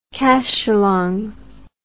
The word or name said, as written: cacholong